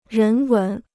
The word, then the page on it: 人文: rén wén